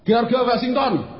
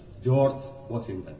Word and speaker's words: George Washington: George Washington. – Betul. Bukan George. – Apa? – Bacanya jangan letter lah. – George Washington.